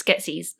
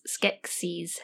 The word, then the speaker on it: Skeksis: It's Skeksis. Skeksis.